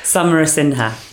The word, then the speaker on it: Samarasinhe: So I'm going to have a go. Apologies already in advance. But Samarasinhe. – Almost. – Oh, okay, you help so us close. Out, please. – Samarasinhe.